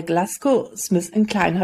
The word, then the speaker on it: GlaxoSmithKline: Er ist Arzt und Chief of Staff und Performance Excellence bei Glasgow Smith Klein. Habe ich das jetzt richtig ausgesprochen, Christian? – Ja, das hast du sehr richtig ausgesprochen,